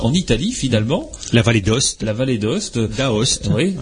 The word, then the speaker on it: Aoste: Chacun ses références, ils disent Valais d'Ost et euh, ouais mais ça s'écrit à Ost effectivement.